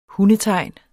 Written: hundetegn

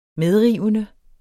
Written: medrivende

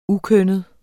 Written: ukønnet

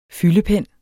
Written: fyldepen

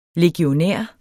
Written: legionær

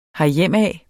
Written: herhjemad

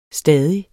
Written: stadig

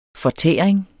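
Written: fortæring

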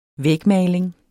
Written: vægmaling

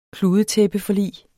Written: kludetæppeforlig

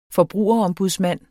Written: Forbrugerombudsmand

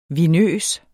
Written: vinøs

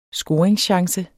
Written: scoringschance

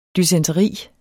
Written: dysenteri

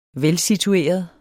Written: velsitueret

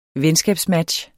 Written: venskabsmatch

